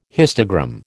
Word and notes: histogram: American English

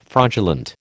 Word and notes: fraudulent: American English

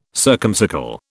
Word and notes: circumcircle: British English

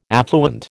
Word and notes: affluent: American English

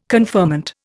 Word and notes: conferment: Indian

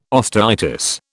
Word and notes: osteitis: British English

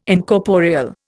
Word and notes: Incorporeal: Indian

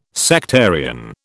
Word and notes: sectarian: British English

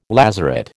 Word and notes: lazaret: American English

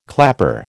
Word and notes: clapper: American English

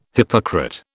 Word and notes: hypocrite: British English

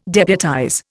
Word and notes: deputize: Indian

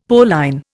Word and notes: Pauline: Indian

